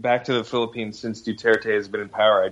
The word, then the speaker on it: Duterte: Is that I think it's Duterte, yeah.